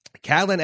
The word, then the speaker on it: Catelyn: and Caitlyn says that her mother and Brienne, uh, wait, sorry, Catelyn asks about her mother. Let me pronounce her name correctly first.